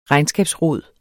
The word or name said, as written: regnskabsrod